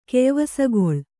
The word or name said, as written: ಕೆಯ್ವಸಗೊಳ್